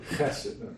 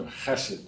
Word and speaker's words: chesed: The Hebrew word is chesed. The Hebrew word is chesed. H-E-S-E-D. Sometimes you'll see it written C-H-S-E-D, trying to put in writing the guttural sound to chesed. That's really how you pronounce it.